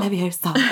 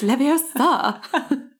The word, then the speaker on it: Leviosa: So it's not Leviosa. It's Leviosa.